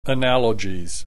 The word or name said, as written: analogies